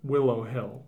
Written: Willow Hill

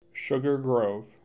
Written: Sugar Grove

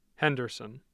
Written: henderson

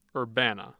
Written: Urbana

